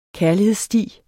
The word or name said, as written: kærlighedssti